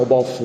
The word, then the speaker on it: Boff: Sì, noi diciamo Boff in Italia, non... anche in Brasile, va bene, si vede che sono io che sono stato in posti in Brasile dove la F finale non si diceva mai, ma si diceva sempre una vocale.